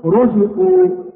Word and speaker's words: رزقوا: مثال مضمومة: كلمة رزقوا، هكذا تنطق رو، ما نقول رزقوا رو، وإنما نفخم الراء فنقول رزقوا. وبالنسبة للمفتوحة وصلا ووقفا أيضا مثل كلمة مبشرا، الراء هكذا تنطق را مبشرا، ما نقول مبشرا، هذه الراء هنا مرققة.